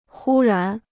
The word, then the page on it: 忽然: hū rán